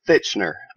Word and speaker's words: Thitchener: It's Thitchener.